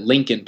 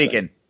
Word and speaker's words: Leakin: It's spelled L E A K I N, but everyone who lives there calls it Leaking Park, and that's where like, like you're likely. To, yeah, exactly. Leaking.